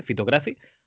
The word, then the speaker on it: Fitocracy: Phytocracy, o como se pronuncia, Fitocracy, ¿vale?